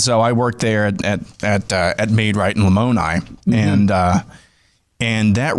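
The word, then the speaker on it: Maid-Rite: So I got a job at this place called Maid Right. It's spelled MAIDRITE.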